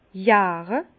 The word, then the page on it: järe: Bas-Rhin; Herrlisheim